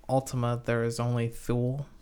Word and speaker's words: Thule: I read it as Thule, but, you know, as in Yule.